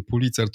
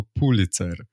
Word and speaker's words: Pulitzer: że właściwie to wszyscy źle wymawiają tę nazwę, bo to nie jest żaden Pulitzer, tylko Pulitzer.